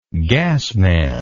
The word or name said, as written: gasman